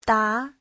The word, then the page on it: da: da2